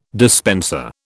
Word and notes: dispenser: British English

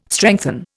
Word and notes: strengthen: Indian

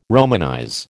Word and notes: romanize: American English

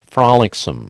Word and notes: frolicsome: American English

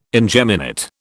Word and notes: ingeminate: British English

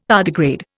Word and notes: tardigrade: Indian

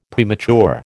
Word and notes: premature: American English

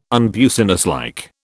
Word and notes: unbusinesslike: British English

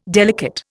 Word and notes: delicate: Indian

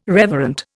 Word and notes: reverent: Indian

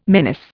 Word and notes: menace: Indian

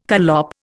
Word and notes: collop: Indian

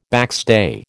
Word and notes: backstay: American English

